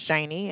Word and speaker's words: Shaney: It's Shani, and I do spell that S-H-A-N-E-Y.